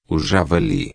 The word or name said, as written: javali